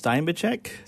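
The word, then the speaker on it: Steinbachek: Is that how you pronounce his name? Steinbecker? – I believe, according to an interview on YouTube, it's Steinbecek.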